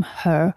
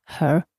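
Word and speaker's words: H.E.R.: I niektórzy na początku y, wymawiali to po prostu y, H-E-R, ale nie. Normalnie się ją czyta her, tak jak się mówi ten zaimek w języku angielskim.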